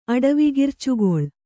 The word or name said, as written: ಅಡವಿಗಿರ್ಚುಗೊಳ್